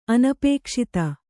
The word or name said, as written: ಅನಪೇಕ್ಷಿತ